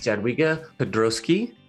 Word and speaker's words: Jadwiga Pyzdrowski: My host is Jadwiga Pyzdrowski.